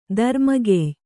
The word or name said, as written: ದರ್ಮಗೆಯ್